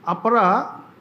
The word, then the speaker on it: apara: And then I found out that in Ayurveda, apara, which is like long A at the end, which is the name actually of this apara.